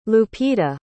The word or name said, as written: lupita